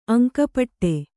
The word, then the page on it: ಅಂಕಪಟ್ಟಿ: kannada